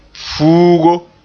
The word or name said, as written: fuugo